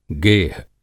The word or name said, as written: गेह